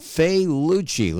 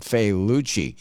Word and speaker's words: Failaci: They're called Failucci. Let me spell that for you. F-A-I-L-A-C-I.